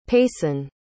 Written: payson

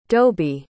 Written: doby

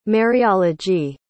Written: Mariology

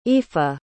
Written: oifa

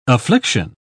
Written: affliction

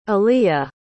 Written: aleah

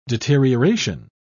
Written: deterioration

